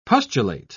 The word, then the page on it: pustulate: British English